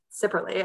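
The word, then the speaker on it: Cipperly: I pronounced it Jim Chipperly, who is Orange Cassidy, and it's Jim Sipperly, and I got like shouted at by my wrestling friends.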